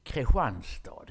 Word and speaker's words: Kristianstad: Eller säger man Kristianstad, säger en del. Kristianstad heter det.